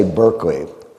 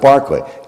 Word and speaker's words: Berkeley: The philosophers don't say Berkeley, they say Barclay. Don't tell me why. I mean, I can't tell you why, but they pronounce it Barclay.